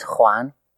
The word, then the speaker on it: Juan: My name is Juan.